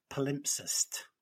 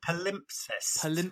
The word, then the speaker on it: palimpsest: Say it again. – Palimpsest. It ends in a T. – Oh, palimpsest. Okay. – Palimpsest. Yeah. P-A-L-M-I. Sorry, hang on. P-A-L-I-M-P-S-E-S-T.